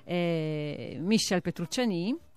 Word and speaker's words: Michel Petrucciani: un grande, ne parliamo al passato perché purtroppo è morto, Michel Petrucciani. – Sì, pronunciandola alla francese, anche se poi insomma anche lui di natali italiani, di origine italiana.